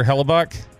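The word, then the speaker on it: Hellebuyck: Connor, and then pronounce it for me, Peyton. Connor Hellebuck. Is that it? – Yes.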